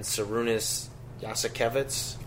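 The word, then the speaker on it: Jasikevičius: One, it sounds like, international candidate in Sarunas Yasikevitz. Did I pronounce it right? – Yeah.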